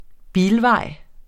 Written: bilvej

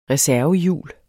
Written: reservehjul

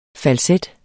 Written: falset

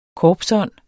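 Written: korpsånd

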